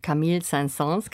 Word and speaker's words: Camille Saint-Saëns: Und so klingt es, wenn sie aus Camille Saint-Saëns Karneval der Tiere.